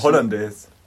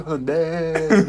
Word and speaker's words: Hollandaise: oder so und dass die Betonung in der Küche eine andere, wie sie eigentlich ist, weil die Betonung ist eigentlich auf das Es am Ende. Ach so, aber wir sagen Hollandaise.